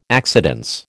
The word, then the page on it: accidence: American English